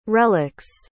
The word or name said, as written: relics